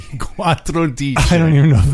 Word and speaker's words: quattordici: Reason number one, I don't remember how to say 14. – Well, let's figure it out. How do you say 10? – Well, cento, that's 100. – Quattro. – Dodici, undici, dodici, tredici, quattordici. Quattordici. – There you go.